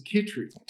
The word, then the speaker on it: Chytry: So my last name is Chytry.